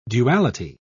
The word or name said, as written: duality